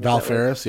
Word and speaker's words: Valfaris: Valfaris, yeah. Valfaris. Are you sure it's not are you sure it's not Valfarie? – Valkyrie. – Maybe. – Profile. Valfaris.